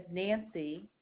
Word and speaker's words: Nancy: Last name is spelled H-A-R-R-I-M-A-N. My name is Nancy, N-A-N-C-Y, but I'm calling on behalf of my husband, who is a policyholder.